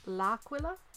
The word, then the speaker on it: L'Aquila: It's Aquila. That is Aquila. That is where the bishop's from. But it's L'Aquila. L, Aquila. Yeah.